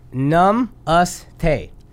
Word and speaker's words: Namaste: Namaste.